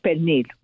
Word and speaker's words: pernil: Yeah, and of course the thing that I can make every year, and I usually have to find pasteles, is pernil. – Mm-hmm. That's what I was telling Ray. We would make that on Sunday. The pernil. Pernil was the proper way to say it, but pernil.